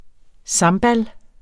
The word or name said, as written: sambal